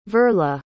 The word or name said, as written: verla